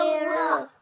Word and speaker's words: الرحمن: باء كسرة، سين سكون، ميم كسرة، نون جلالة لا يقطع، ألف وصل في وسط الكلام لا ينطق، لام شمسية مضغمة لا تنطق، راء شدة وفتحة مفخمة، بسم الله الرحمن،